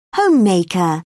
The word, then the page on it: homemaker: British English